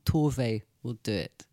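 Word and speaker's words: Tove: Can't give the definitive, but having hung out with Sophia Jansson, Torve's niece, she's always said Torve.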